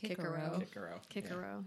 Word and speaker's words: Cicero: and I found reference to basically they had no soft C's. So like Cicero, who you mentioned earlier, would have been pronounced Kickero.